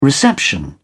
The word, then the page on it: reception: British English